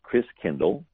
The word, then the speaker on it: Christkindl: And the German pronunciation of Christ child is Christkindl, like kindergarten, kindercare.